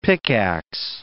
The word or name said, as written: pickax